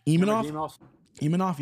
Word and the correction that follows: Imavov: yeah. – Imavov. There's no N in it.